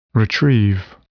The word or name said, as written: retrieve